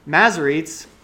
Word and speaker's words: Masoretes: And so what happened with the Masoretes, that's how you pronounce it, I figured it out, is they added those vowel sounds in there.